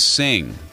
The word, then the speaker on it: Singh: Vijay Singh. You have an alternative performance and pronunciation of his last name, right? – Vijay Singh. – Singh. Yes.